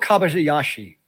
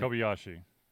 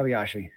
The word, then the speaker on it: Kobayashi: Takiro Kobayashi, I totally butchered that problem. – Kobayashi, I think. – Kobayashi. – Yeah, there you go.